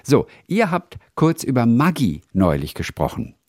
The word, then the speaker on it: Maggi: Dass das Produkt eigentlich italienisch ausgesprochen wird, also wie in Lago Maggiore, Maggi.